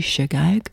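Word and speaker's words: chagag: You know, that Hebrew word for feast, well, I don't know Hebrew, I don't know how to pronounce it properly, but it's C-H-A-G-A-G, Shagag, which also means to dance.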